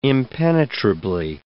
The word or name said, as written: impenetrably